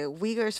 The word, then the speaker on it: Uyghurs: Well, in the book, there are characters who are refugees, and they're Uyghurs. Is that how we pronounce it? – Yes. Yeah. – Okay. Uyghurs from China.